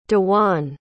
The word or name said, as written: dewan